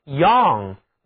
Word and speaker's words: young: young writers 在这个短语中，年轻就是 young， y-o-u-n-g。在这个单词当中，字母 y 读音是 y-o-u，字母组合读作三角元音啊，还有 NG 组合起来是读后鼻音，嗯，年轻人就是 young people， young people，而老老少少这就是短语 young and old， young and old。